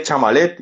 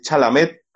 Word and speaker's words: Chalamet: Yo decía Chamalet y es Chalamet.